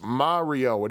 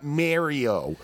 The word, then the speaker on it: Mario: That's all I have to say about Mario Brothers, except that, ladies and gentlemen, Mario, that is an Italian name. It is pronounced Mario. It is not Mario.